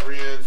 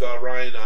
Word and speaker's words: Rian: So many people were mad about Rian's direction with the movie that J.J. – Oh, is that how you say it? – Actually, it is Ryan. Okay. I always call him Rian because I'm fancy.